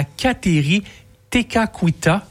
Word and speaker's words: Kateri Tekakwitha: À Caughnawake, on va pouvoir aussi découvrir le sanctuaire qui va être dédié à Kateri Tekakwitha.